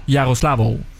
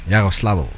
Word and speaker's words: Yaroslavl: Yaroslavl. – Faut prononcer le L à la fin. – Ouais. – Yaroslavl.